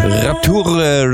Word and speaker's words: Rapture: Rapture, Rapture, genau, das war schon französisch ausgesprochen. Ja, genau. Wurscht, du kannst so sprechen. Ja, das war absolut richtig und genau.